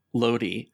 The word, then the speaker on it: Lodee: I believe right towards the end of our last encounter, Lodi had made an appearance. Did I say that right? – Yeah, you did. – Awesome.